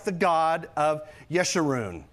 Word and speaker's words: Yeshurun: Now, that's not Jeshurun, okay? – And I'll tell you, all my years, I've always pronounced it Jeshurun. I learned my lesson this because when I started researching this, there's another pronunciation, the right one.